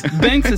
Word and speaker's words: binks: Banks, ça veut dire donc le quartier, hein. Ça s'écrit B-I-N-K-S, mais on le prononce Banks.